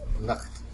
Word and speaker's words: Nacht: N-A-C-H-T-K-R-A-P-P. – N-A-C-H-T. – Nak-krap. Krap? – Let me see the writing. – All right. – Nacht. – Yeah, it's Nacht. It's a night something. – Nacht-krap. – Nacht-krap? – Nacht.